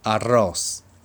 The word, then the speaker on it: arroz: arroz, arroz, arroz ご飯という単語です。